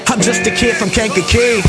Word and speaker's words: Kankakee: Kankakee! I didn't know how to say it, but I do now.